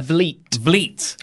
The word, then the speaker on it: Vliet: Then we got Chris Van Vliet. That's how you say it, isn't it? – Vliet. Like fleet.